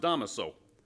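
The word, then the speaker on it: Dámaso: And it is pronounced Damaso.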